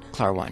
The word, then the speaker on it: Klarwein: It's an artist by, do you pronounce Matti Klarwein? – Klarwein, right.